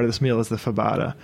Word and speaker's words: fabada: So obviously, this meal would be incomplete without, how do you say it, fabada.